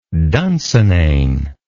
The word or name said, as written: dunsinane